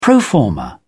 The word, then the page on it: pro forma: English; British English